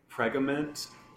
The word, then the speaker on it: Pregament: A sticker on the keyhole saw used to dismember Mulcahy was traced back to the Pregament retail chain. It sounds like I'm trying to say pregnant and I'm saying it wrong, but it's Pregament.